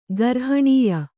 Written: ಗರ್ಹಣೀಯ

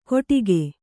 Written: ಕೊಟಿಗೆ

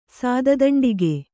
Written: ಸಾದದಂಡಿಗೆ

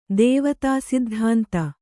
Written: ದ್ವೈತಸಿದ್ಧಾಂತ

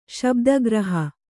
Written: ಶಬ್ದಗ್ರಹ

